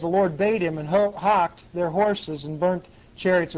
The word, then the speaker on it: houghed: It's pronounced hocked, H-O-U-G-H-E-D. Okay, see that? They put a C-H in there. If you look it up in Webster's Dictionary, this word is pronounced hock, H-O-K. Okay, that's how it's pronounced.